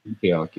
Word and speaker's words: Antioch: How do you pronounce this? Antioch? – Antioch, yeah.